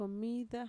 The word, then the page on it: komida: Mixtec